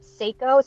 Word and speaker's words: Sseko: Wait, can you say it again? – Yep, Seiko. So it's spelled S-S-E-K-O. – Okay, S-S-E-K-O Designs.